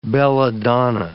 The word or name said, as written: belladonna